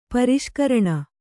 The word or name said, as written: ಪರಿಷ್ಕರಣ